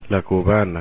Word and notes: cubana: Spanish

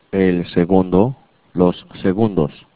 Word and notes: segundo: spanish

